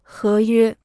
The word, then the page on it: 合约: hé yuē